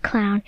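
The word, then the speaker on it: Clowne: The town is called Clown. It's spelt like the clown as in a circus, but with an E on the end.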